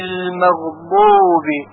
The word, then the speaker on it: المغضوب: انعمت عليهم غير المغضوب، غير المغضوب. در کلمه غیر و همچنین در کلمه مغضوب دو حرف غین وجود داره که همونطوری که میدانید حرف غین از حلق تلفظ میشه،